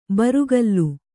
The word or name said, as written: ಬುರುಗಲು